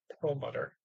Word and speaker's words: Perlmutter: Us Jews pronounce him Perlmutter.